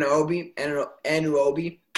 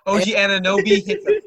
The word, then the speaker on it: Anunoby: Anunoby. OG Anunoby. – OG Anunoby. – Yeah, it's not Anna. You got it. It's close. It's A-N-U-O-N-U-O-B. Like, it's a quick U.